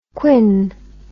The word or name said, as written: Quinn